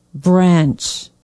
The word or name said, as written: branch